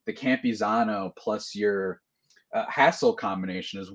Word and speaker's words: Campusano: It's Campusano.